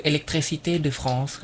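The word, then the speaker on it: électricité de France: ถ้าเต็มๆมันคือ electricité de France ครับ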